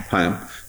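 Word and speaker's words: Haim: The interesting thing for me was that it was really about him because Alana, the actress, how do you say her last name again? – Chaim. – Yeah. – Chaim.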